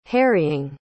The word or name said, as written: harrying